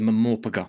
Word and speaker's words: MMORPG: It's a Mamorpaga. Do you remember Mamorpaga? Mamorpaga. – It is MMORPG or Mamorpaga. Anything else? – That is the correct pronunciation.